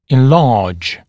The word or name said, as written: enlarge